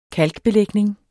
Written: KALK

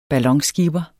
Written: BALLONSKIPPER

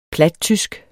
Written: plattysk

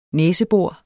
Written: næsebor

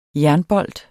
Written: jernbolt

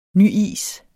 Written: nyis

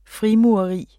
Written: frimureri

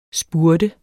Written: spurte

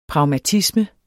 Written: pragmatisme

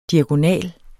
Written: diagonal

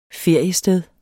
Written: feriested

